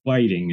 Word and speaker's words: Whiting: Am I saying it right? – Whiting is how they say it. – The Whiting Writers Award. That's exactly how it's spelled. I just got it wrong.